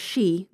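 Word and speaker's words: shi (し): The Japanese four is pronounced shi, which sounds like death. – Yeah. – And so sometimes for numbers, they use a different word, which is yon, which also means four.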